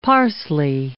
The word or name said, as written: parsley